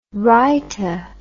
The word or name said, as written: rhyta